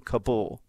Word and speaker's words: Kabul: Now, this was an attack in downtown Kabul, as it's now correctly pronounced.